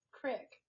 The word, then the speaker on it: creek: It's a creek. – E. E-E-K is eek. Creek. – C-R-I-C-K, crick. – Okay, but that's not even how you fucking spell it. – That's what we call it, crick.